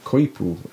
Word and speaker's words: coypu: Okay, so they're also known as a koipu, which is C-O-Y-P-U, koipu. – Koifu. – C-O-Y-P-U. – P-U. Quit. – like a koi, like a fish, and then poo, like, well, like poo, I guess. I think that's how it's pronounced. That's, oh, koi poo? – Yeah, or quapu? Koi, I think it's koi poo.